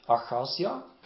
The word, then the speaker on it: Achazia: We see that Ahaziah, is that correct? Ahaziah, is that the English pronunciation? – Achazia, okay.